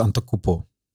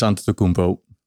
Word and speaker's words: Antetokounmpo: Eh, Giannis Antetokounmpo okay, lettergreep overgeslagen. Oh. Ah. – uh, eh, MVP Janis Antetokounmpo.